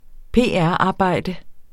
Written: PR-arbejde

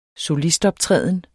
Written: SOLIST

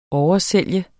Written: oversælge

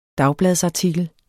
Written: dagbladsartikel

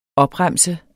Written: OPREMSES

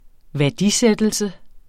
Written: værdisættelse

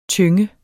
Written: tynge

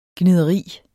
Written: gnideri

